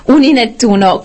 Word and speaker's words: Uninettuno: E in Italia infatti c'è anche un'altra piattaforma che si chiama Uninettuno. Pronuncio bene, spero. – Sì. – Uninettuno,